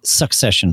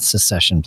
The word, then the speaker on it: succession: One, succession planning, not secession planning, because succession is different. Sorry, I mispronounced that.